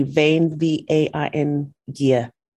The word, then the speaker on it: Veiqia: That's spelled V-E-I-Q-I-A. And if you wish to pronounce it, it's say Vain, V-A-I-N, Gia.